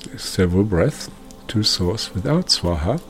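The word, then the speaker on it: Swaha (स्वाहा): Now, when this love goes back to source from your side, think Swaha. like swan and ha-ha, swan and ha-ha, like swaha, swaha.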